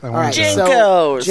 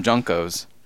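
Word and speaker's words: JNCO: How do you pronounce the name of the big pants? – Jinkos. – They're Jinkos, but one person on Earth calls them Junkos. – And Alex Kirchner calls them JNCOs.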